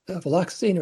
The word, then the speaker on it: Viloxazine: Yes, it's Viloxazine, or extended release Viloxazine, because it's a formulation that extends over a longer period of the day, requiring fewer doses. The first important fact about Viloxazine is that it is a non-stimulant medication,